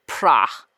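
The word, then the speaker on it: Prà: But if I had had access to it, my favorite Valpolicella producer is Pra, which is just P-R-A with a little accent on the A.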